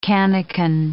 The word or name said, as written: cannikin